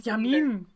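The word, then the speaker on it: Jamin: He won one of the races this weekend, and Nico Yaman? I can't pronounce his name, and I feel like a douche. – It's French. I'm not sure. – It's French. Yamin.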